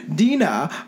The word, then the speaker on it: Dinah: the disturbance is centered around the Dina, a Scottish orphan. Dina. Her name's literally Dina, but it's spelled super weird. It's spelled Dina. – D-E-E-N-A? – D-I-N-A-H.